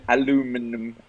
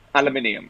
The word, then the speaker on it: aluminium: Al- aluminum, aluminum, aluminium. Aluminium is what is that we'd say. Is British people? – Do British people say aluminium and Americans say aluminum?